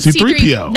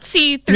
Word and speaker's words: C-3PO: It's like one word, C-3PO. Not C-3PO. Not C-3PO. – Yeah, you say R2-D2 and C-3PO. – You say C-3PO. No, C-3PO. That was the name. – The emphasis on the P is kind of...